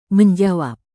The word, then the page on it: menjawab: Indonesian